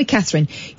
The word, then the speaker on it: Katherine: Katherine. They call me Katherine.